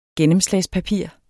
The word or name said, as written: gennemslagspapir